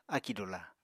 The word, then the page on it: akì dula: apatani